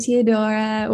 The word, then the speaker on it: Teodora: Hi, Teodora.